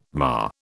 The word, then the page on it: mar: British English